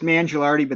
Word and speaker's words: Mangialardi: And it's Mangialardi, but that's okay.